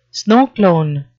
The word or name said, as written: snowclone